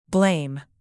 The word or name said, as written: blame